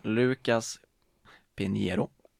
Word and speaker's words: Lucas Pinheiro: Lucas Pinheiro, som var brasilianska, och Braten, som är norska.